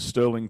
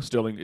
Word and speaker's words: Stirling: Yeah, Instagram is just my name, at Stirling Cooper. Sterling is spelled S-T-I-R-L-I-N-G.